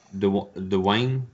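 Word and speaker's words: Dewan: It's Jenna Dewayne. That's how you say her name.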